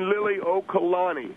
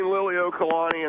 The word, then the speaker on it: Liliʻuokalani: Well, first of all, Phil, I want to just correct you on the pronunciation of Queen, the Queen's name, or her name is Queen Liliʻuokalani. – Liliʻuokalani? – Queen Liliʻuokalani, and that is the last monarch of the Hawaiian Islands.